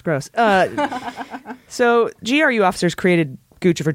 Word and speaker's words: Guccifer: Then there's Guccifer 2.0, which is pronounced Guccifer because it's a combination of Gucci and Lucifer.